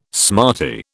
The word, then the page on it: smarty: British English